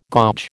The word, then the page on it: gouge: American English